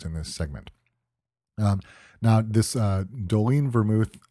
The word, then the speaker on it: Dolin: If you look for it on a shelf, it's spelled D-O-L-I-N, but it is pronounced Dolin.